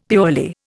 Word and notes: purely: Indian

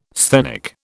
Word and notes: sthenic: British English

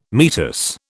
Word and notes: meatus: British English

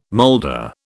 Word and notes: moulder: British English

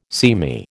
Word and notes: seamy: American English

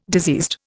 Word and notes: diseased: Indian

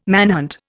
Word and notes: manhunt: Indian

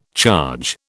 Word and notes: charge: British English

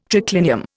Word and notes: triclinium: Indian

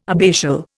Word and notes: abbatial: Indian

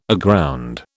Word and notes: aground: British English

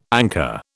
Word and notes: anchor: British English

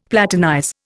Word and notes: platinize: Indian

